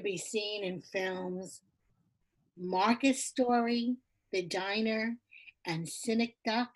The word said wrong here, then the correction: Synecdoche: I think that's, I'm saying that correctly. – Synecdoche. – Sorry, Synecdoche, boy.